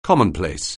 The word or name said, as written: commonplace